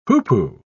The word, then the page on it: hoopoe: British English